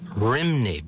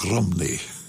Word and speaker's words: Rhymney: We knew it for years, Pete, or we called it the Bells of Rimney, but that's not accurate, is it? – That's how it's spelt. R-H-Y-M-N-E-Y. But there they pronounce it more like Rumney.